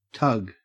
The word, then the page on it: tug: Australian English